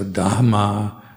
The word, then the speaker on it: dhamma: And also try to pronounce all the H's. So dhamma, buddha, sangha.